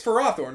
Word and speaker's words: Ferrothorn: It's we, we it's Ferrothorn, right? That's what your buddy who did the. – the I believe that's the official pronunciation.